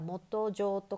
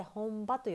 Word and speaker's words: 本場: 漢字の読み方これ本場えー、読む本に場所の場で本場これは元上とかじゃなくて本場と読みます。